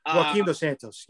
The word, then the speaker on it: Joaquin Dos Santos: Joaquin Dos Santos, yeah.